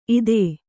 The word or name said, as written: ಇತ್ತು